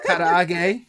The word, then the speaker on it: karaage: Monica helped me correct the pronunciation on that all night long. I don't know if she was under the influence or not, but yeah, she kept saying it multiple times. I was like, okay, I get it. I get how to pronounce karaage. So. – Except you were rolling your R's, so that made it even yes.